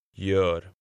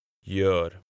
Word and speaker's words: Gör: Gör. Gör. – In Swedish, the letter G can make a soft sound, like the Y in yes, when it is followed by the vowels E, I, Y, dotted A, or dotted O.